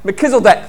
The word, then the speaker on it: Melchizedek: Or however you want to say it, Melchizedek. How do you pronounce it? Melchizedek, thank you.